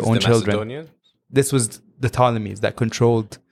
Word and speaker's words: Ptolemies: So we all know that the Ptolemies are... – Ptolemies spelled P-T. – Yeah, with P-T. A silent P. – With a silent P, yeah.